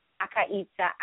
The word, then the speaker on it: Akaitza: It's Jamar Akaitza. I will spell it. because they're all weird, funky names, just like me, original. It's J-I-M-A-R, separate name, A-K-A-I-T-Z-A.